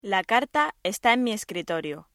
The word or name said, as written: escritorio